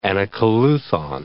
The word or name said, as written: anacoluthon